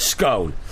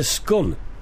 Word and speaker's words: scone: It's cone with an S in front. – Yes. Mm. So it's scone. Mm. Somebody here has said scone rhymes with done. It's a scun.